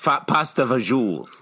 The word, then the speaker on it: pasta fagioli: Because when I was a kid, I always loved having pasta fagiola, I think it's pronounced pasta fagioli.